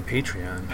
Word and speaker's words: Patreon: I say Patreon. – Patreon. I always, being Polish, I put the accent on the penult, so second to last syllable, because that's how we do.